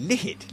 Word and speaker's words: Lihit: The, how do you say this? L-I-H-I-T, Lihit, Lihit